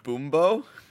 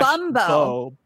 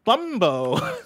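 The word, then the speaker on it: Bum-bo: Boombo. – Uh, Boom, Boombo is spelled B U M dash Bumbo. Bumbo.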